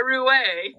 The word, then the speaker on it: roué: A roué, yeah. – What's that? I've never heard that. – Oh, a roué? Yeah, it's R-O-U-E with an accent on the E.